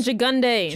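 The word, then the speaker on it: Gigandet: Cam Gigantid as James. – Cam Gigunde. – Gigunde. I've never said that out loud. That's how it's said.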